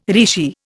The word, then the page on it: rishi: Indian